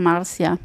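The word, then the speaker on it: Márcia: Ich würde sagen, Escuta Formosa Marcia, und richtig klingt es? – Escuta Formosa Marcia. – Also wieder die Betonung auf der zweiten Silbe des Vornamens. – Einfach Marcia. – Wie Lea. Marcia.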